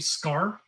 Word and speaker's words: Skaar: Scar, S-K-A-A-R. – It's like Sakaar, yeah. It's like Scar, but it's spelt in a weird way.